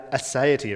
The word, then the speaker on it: aseity: Uh, aseity, it rhymes with deity.